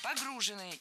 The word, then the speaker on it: погружены: Погружены – неверно. В этом контексте неверно. Погружены вещи в машину, погружены товары – это правильно. Но если мы говорим не о конкретном грузе, то уже погружены. Ударение ставим на окончание.